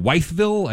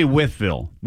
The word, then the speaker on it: Wytheville: Could be Wytheville. – Wytheville. Could be either way. – W-Y-T-H-E is usually pronounced with.